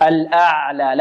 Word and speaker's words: الأعلى: ربه الأعلى، العين نعطيها صفة ماذا؟ التوسط، الأعلى. لا نقول الأعلى أعلى أعلى، لا، أو الأعلى أعلى، البعض ينطق هكذا يفخم العين الأعلى، هذا خطأ، وربما يفخم معها الهمزة أعلى. ربه الأعلى، لا خطأ، الأعلى أ، الهمزة مرقة والعين مرقة ومتوسطة، الأعلى أعلى، مقدار حركتين الألف.